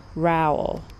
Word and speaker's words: Rowell: One word, Fangirl by Rainbow Rowell. Rhymes with Dowell, R-O-W-E-L-L.